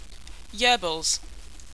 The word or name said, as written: yurbles